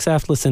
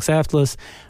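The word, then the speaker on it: Saftlas: I think I'm the only Yitzhak Saftless in North America, so it should be easy to find any way you spell it.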